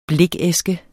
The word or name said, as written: blikæske